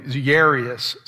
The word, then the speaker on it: Jairus: Now, I've always grown up learning his name as Jairus, but I looked it up and it has a soft J sound, so it would be like Yahweh, it would be the Y sound, and I still can't pronounce it right. Jairus, something like that would be the correct way to pronounce it.